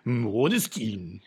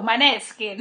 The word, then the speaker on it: Måneskin: danois, c'est moi qui dois le prononcer, du coup, tu sais très bien le danois. – Maneskin. – Måneskin. – Mais ils sont italiens, donc je me disais plutôt Maneskin.